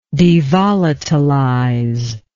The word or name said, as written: devolatilize